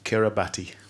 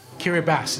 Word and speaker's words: Kiribati: Indonesia and Kiribati. – Kiribati, it's pronounced.